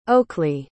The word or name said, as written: Oaklee